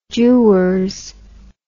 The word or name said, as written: dewars